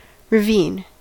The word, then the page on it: ravine: American English